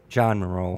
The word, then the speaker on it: Moreau: But, and Jean Moreau, am I pronouncing that correctly? – John Moreau. – Yeah, I knew I'd get it wrong.